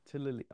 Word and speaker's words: Toulouse: Yes, Toulonce. Toulonce. Toulouse. I thought it's Toulouse. Toulouse.